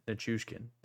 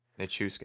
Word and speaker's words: Nichushkin: Nichushkin. Um, Nichushkin. – Nichushkin. There we go. Nichushkin.